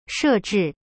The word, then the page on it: 设置: she zhi